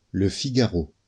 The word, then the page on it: Le Figaro: lə fiɡaʁo; French